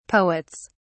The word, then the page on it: poets: British English